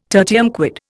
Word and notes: tertium quid: Indian

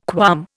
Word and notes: qualm: Indian

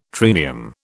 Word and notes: triennium: British English

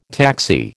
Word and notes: taxi: American English

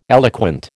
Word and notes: eloquent: American English

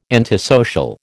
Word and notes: antisocial: American English

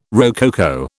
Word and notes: rococo: British English

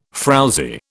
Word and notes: frowzy: British English

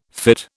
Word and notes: fytte: British English

